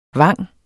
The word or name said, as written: vang